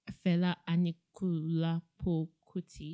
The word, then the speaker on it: Fela Anikulapo Kuti: Yeah, Femi Kuti is the son to the late Fela Anikulapo Kuti.